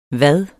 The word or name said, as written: vad